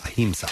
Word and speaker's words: Ahimsa: A-H-I-M-S-A. – So I'm guessing that's how you pronounce it. Ahimsa.